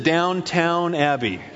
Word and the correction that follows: Downton: which makes the punchline, right? It's my wife's working on me. It's Downton, something like that.